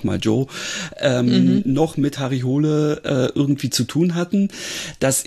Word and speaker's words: Hole: den ich mit seiner Harry Hole oder Hule, glaube ich, wird es ja ausgesprochen, Reihe total mag.